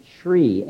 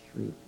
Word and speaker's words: Shri: Is Shri, S-H-R, Shri. You could phonetically spell it S-H-R-E-E. It's usually spelled S-H-R-I, but it's Shri.